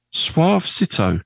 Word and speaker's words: Suavecito: Suave Cito. It's S-U-A-V-E. C-I-T-O. Suavecito.